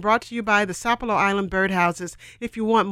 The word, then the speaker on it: Sapelo: and that is spelled S-A-P-E-L-O, Sapelo. Some people call it Sapelo, but the local folks call it Sapelo, right, Doc Bill? – They call it Sapelo.